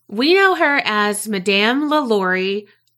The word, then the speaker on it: LaLaurie: However, I learned that I was a peasant And Carrie told me the correct pronunciation, and it is La Lurie.